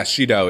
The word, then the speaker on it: Shido: Is that pronounced correctly? Shido? – Yeah, Shido. It's seed in Japanese.